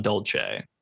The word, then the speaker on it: Dulce: Dulce is D-U-L-C-E. It's in Dulce. New Mexico. – Which apparently, according to the podcasts that I've been listening to, it is Dulce. It's not Dulce, which is unfortunate.